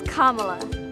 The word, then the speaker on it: Kamala: It's not Kamala. It's not Kamala. – It's Kamala.